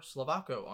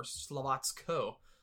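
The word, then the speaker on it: Slovácko: Partizan on eight, Nice on five, Cologne on four, Slovako on four, or Slovatsko, as I've heard it pronounced by somebody more in the know.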